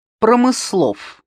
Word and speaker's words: Промыслов: Промыслов. – Ударение на второе густое «о».